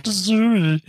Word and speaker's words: Zooey: It's pronounced Zooey.